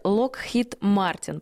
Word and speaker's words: Lockheed Martin: Поправьте меня, пожалуйста, не знаю, как правильно сказать, искала в интернете Lockheed Martin, правильно? Да. Правильно говорю? – Да, да, есть такая компания, да.